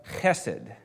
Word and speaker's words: chesed: He said that we should love chesed. You hear that good guttural sound of when you pronounce Hebrew, it's like clearing your throat.